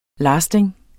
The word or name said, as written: lasting